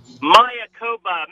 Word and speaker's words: Mayakoba: Okay, you're going to have to help me pronounce this. You ready? – Mayakoba, Mexico. There we go.